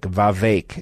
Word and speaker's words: Vivek: The pronunciation of Mr. Ramaswamy's first name is Vivek. That's right. And I've been corrected on that several times by someone who's deep inside his campaign. It's as in lake, Vivek.